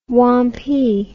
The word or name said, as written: wampee